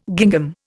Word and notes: gingham: Indian

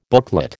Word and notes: booklet: American English

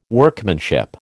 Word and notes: Workmanship: American English

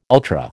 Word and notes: ultra: American English

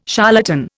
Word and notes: charlatan: Indian

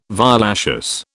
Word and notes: violaceous: British English

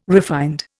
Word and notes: refined: Indian

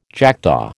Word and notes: jackdaw: American English

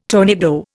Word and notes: tornado: Indian

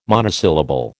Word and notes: monosyllable: American English

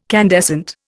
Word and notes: candescent: Indian

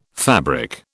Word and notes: fabric: British English

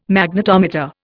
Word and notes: Magnetometer: Indian

